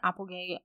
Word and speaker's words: Applegate: If you just search my name, Carmen Applegate, Applegate spelled like an apple and a gate, and then you can check out some of my stuff.